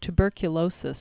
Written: tuberculosis

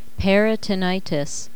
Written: peritonitis

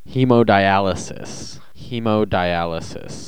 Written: hemodialysis

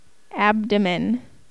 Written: abdomen

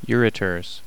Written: ureters

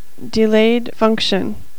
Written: delayed function

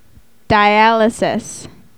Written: dialysis

